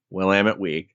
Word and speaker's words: Willamette: Willamette Week.